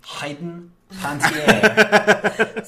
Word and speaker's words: Panettiere: I believe it's pronounced Hayden Panettiere.